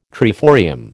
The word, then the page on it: triforium: American English